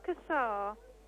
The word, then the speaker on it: Cassar: My name is Captain Seddon, S-E-D-O-N. May I know who I'm speaking to? – You're speaking to Mrs. Cassar. – Could you kindly spell it? – C-A-S-S-A-R.